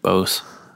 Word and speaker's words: Bose: Boss speaker thing, Bose, whatever the fuck it's supposed to be pronounced. – I always pronounce it Bose. – Bose. Yeah. I don't know. I've always pronounced it Boss, but. – Boss is a different company that has very two S's.